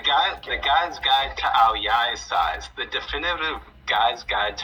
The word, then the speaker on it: Ao Dai: Oh, well, and Remy is telling um, Remy is telling me it's pronounced Ao Yai, not Ao Dai. It's spelled with a D uh, though, right?